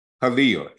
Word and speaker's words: Helel: Halil, H-E-L-E-L. That's how you pronounce it in Hebrew.